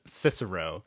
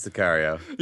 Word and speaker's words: Sicario: As a friend of mine whose name will not be said, to protect the innocent, pronounced it Cicero. – Nope. – And I quickly corrected him that is not correct. – I'm going with Sicario. – Yeah, exactly. His defense. Yes, it's definitely Sicario.